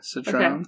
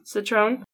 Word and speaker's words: Citroën: Is that how you say the car? – What? – Sure. – Citroën. – Okay. Citroën. – You don't have to pronounce every letter.